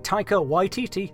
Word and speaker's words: Taika Waititi: Slash Film have reported that dozens of filmmakers, including, but not limited to, Martin Scorsese, Taika Waititi, that's how you say his name, Sofia Coppola, Seth Rogen, Greta Gerwig, Barry Jenkins, Kathy Yan, Michael Bay, Jude Apatow, Edgar Wright, Alfonso Cuarón, M.